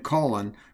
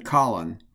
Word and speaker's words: Colin: Powell pronounced his name Colin rather than Colin. He did this in honor of Colin Kelly, a hero from World War II.